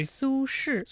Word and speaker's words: Su Shi: Su Shi.